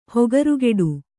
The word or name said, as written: ಹೊಗರುಗೆಡು